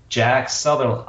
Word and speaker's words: Jack Sutherland: It is Jack Sutherland.